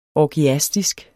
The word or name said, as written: orgiastisk